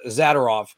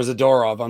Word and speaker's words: Zadorov: But if Nico Hischier's back, that really helps your defense more than a player returning on them making the addition to Zadorov or Zadorov. I'm sorry. I put the wrong emphasis on the wrong syllable.